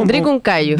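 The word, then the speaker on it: Drikung Kagyu: Estamos con profesora de literatura, instructora de yoga y bailarina de la comunidad budista Drikung Kayu. ¿Lo dije bien? ¿O? – Drikung Kayu.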